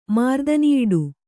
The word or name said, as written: ಮಾರ್ದನಿಯಿಡು